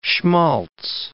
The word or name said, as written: schmaltz